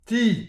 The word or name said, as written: ti